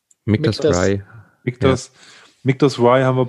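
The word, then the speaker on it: Michter's: Also beim Mictas, die Aussprache, ich habe auch am Anfang gedacht, man spricht das Mitsters aus.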